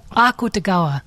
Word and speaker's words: Akutagawa: The stress is on the so, Akutagawa.